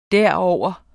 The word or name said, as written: derover